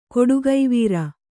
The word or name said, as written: ಕೊಡುಗೈವೀರ